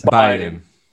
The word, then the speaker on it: Biden: ¿Es Biden o Biden? ¿Cómo se pronuncia? – Biden. – Biden.